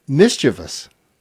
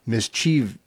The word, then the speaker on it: mischievous: Mischievous. – Okay. – The correct pronunciation is three syllables, not mischievous. Mischievous is what people will say.